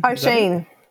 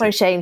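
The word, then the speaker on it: Oisín: Ocean Mullen. – O'Sheen. That's O'Sheen.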